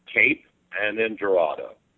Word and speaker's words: Cape Girardeau: How do you say that town? – Cape. And then Girardeau. – It's like a French-sounding word.